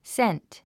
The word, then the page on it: scent: sént; セント